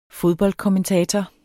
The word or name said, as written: fodboldkommentator